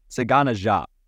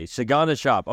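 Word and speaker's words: DeSagana Diop: Okay, Sagana Jop. Okay, thank you. – Why are D-E in front of his first name, but you don't pronounce it? Like, what is that? – Anyway, Sagana Jop played 27 games for the Bobcats that season.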